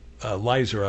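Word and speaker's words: Lizer: Myron Leiser, Navajo Nation Vice President. Leiser, I believe, is the correct pronunciation.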